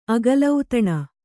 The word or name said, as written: ಅಗಲೌತಣ